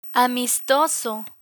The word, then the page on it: amistoso: spanish